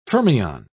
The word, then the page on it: fermion: British English